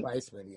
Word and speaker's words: Weissman: Weissman, yeah.